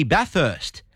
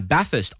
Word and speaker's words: Bathurst: Correct pronounces Bathurst. With an F. It's not Bathurst, it's Bathurst.